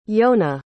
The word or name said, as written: yona